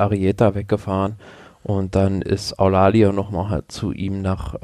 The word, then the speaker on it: Eulalio: Zwei Fahrer, Igor Arrieta und Alfonso Eulalio. Wie spricht man das aus? Eulalio? – Eulalio, ja.